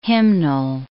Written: hymnal

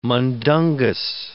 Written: mundungus

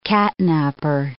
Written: catnapper

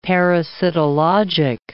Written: parasitologic

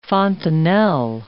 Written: fontanel

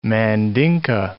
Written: Mandinka

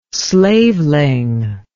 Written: slaveling

